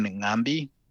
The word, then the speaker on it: Ng'ambi: But yes, my name is Aaron Ngambi, and I'm from Zambia.